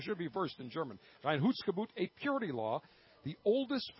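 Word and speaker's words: Reinheitsgebot: In 1516, William IV, the Duke of Bavaria, adopted the Rheingeschbott. I believe I'm pronouncing that correctly. – Rheinhutzgebot.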